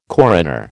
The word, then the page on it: coroner: American English